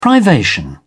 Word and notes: privation: English; British English

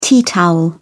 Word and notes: tea towel: British English

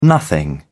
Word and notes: nothing: British English

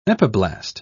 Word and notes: epiblast: British English

